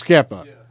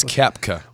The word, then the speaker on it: Koepka: You cannot get his name right. – Jason Martin's about to join us right now. – God. Brooks Koepka.